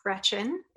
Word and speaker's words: Greczyn: So my name is Alice Greczyn. It's pronounced just like the girl's first name, even though it's spelled with a CZ in there.